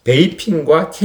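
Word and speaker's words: 베이핑과 (北平): 최대한 그럼 중국어 발음으로 베이핑과 텐진을 점령하게 됩니다.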